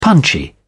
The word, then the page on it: punchy: British English